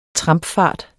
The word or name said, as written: trampfart